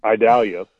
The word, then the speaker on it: Idalia: Idalia, right? – Yeah, that's how you pronounce it.